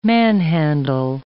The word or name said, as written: manhandle